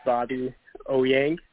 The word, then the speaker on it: Bobby Ouyang: My name is pronounced Bobby Ouyang, really simple.